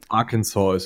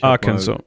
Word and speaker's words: Arkansas: Das heißt, äh, Arkansas oder Arkansas, wie immer das ausgesprochen wird, Tennessee, ähm, South Carolina, Georgia, Alabama, Mississippi, Louisiana, Texas und Florida. Das sind die Südstaaten. Ähm, Arkansas ist es. Ich Arkansas, okay.